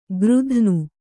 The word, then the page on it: ಗೃಧ್ನು: gřdhnu